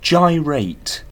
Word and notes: gyrate: British English

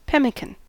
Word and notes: pemmican: American English

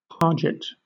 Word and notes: parget: British English

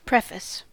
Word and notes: preface: American English

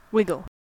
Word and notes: wiggle: American English

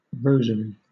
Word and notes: rosary: British English

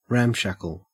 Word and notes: ramshackle: Australian English

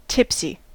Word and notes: tipsy: American English

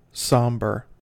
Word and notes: somber: American English

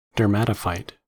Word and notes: dermatophyte: American English

